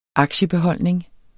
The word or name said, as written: AKTIEBEHOLDNING